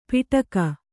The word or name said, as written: ಪಿಟಕ